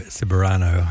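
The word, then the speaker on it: Ceberano: Ciberano. I was just told that I was mispronouncing it incorrectly before.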